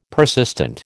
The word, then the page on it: persistent: American English